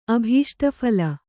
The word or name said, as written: ಅಭೀಷ್ಟಫಲ